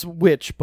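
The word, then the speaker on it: The VVitch: It's witch, but it's spelled with two V's.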